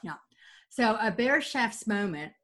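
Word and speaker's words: Bereitschafts: And we owe this bit of fail-safe choice making to what scientists refer to as a Barrett Schaff's moment.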